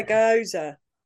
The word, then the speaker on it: gyoza: I say gyoza.